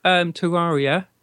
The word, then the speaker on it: Terraria: She likes Terraria.